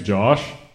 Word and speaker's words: Josh: What am I saying? – I said Jaws. – Josh.